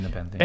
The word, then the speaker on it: Nepenthe: Nepenthe. – I would say Nepenthe.